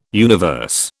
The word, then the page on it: universe: British English